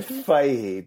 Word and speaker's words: Fahey: It's a live review of John Fahey. Is that how you pronounce it? – Well, I would say Fahey, but it's quite hard.